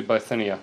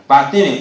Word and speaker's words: Bithynia: Essayed to go to Bithynia. – Bithynia, thank you.